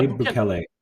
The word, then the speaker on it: Bukele: Well, I can never pronounce his Bukele. – Last name. Bukele. – Yeah, Bukele.